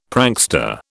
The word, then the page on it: prankster: British English